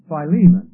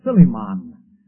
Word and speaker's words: Philemon: There's a little epistle called the Epistle of Paul to Philemon, or you might prefer the pronunciation Philemon. Depends from what part of the world you come. May I remind you that Philemon and Philemon either are acceptable.